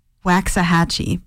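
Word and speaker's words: Waxahatchee: Waxahachie, because she literally pronounces it correctly in the song, so I should have done better.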